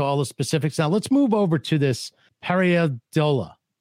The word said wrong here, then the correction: pareidolia: So how do I say it? – J'aime ça beaucoup. Pareidolia.